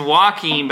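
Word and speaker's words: Joaquín: Is it Joaquin or Joaquin? – I think it's Joaquin, but I just say Jocko because I think that's his nickname.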